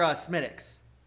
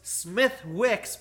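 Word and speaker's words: Smithwick's: No, they actually sell more Smittix. So it's Smith Wicks, but the W is silent, so it's Smith.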